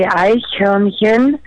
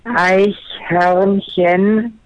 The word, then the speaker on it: Eichhörnchen: So, as I'm a German who can pronounce squirrel, I'm challenging every American to say Eichhörnchen. That's Eichhörnchen.